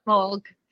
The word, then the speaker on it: Smaug: Smog.